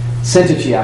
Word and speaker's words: Syntyche: And it may be that it's just exclusively limited to Euodia and Syntyche, I believe is the correct pronunciation. I looked that up once and now I've forgotten it, but I think it's Syntyche.